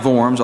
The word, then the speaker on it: Worms: The gathering is called a diet, and it was a place called Worms, pronounced Worms, although it's spelled W-O-R-M-S, Diet of Worms, which is what it looks like in English, but it was a gathering at Worms, Germany.